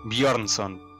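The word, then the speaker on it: Björnsson: No, boludo, si la J entre la B y la O se pronuncia ahí. – Bjornsson.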